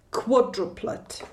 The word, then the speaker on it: quadruplet: Jo, takže quadruplet nebo quadruplet. Jo, jestli chceš to vyslovat krátce, tam je přízvuk na začátku, anebo dlouze. Quadruplet, tam je přízvuk na druhé slabice.